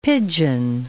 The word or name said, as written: pigeon